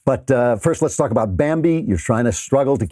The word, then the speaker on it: Bambee: But Bambi, which is spelled B-A-M-B-E-E, was created specifically for small businesses.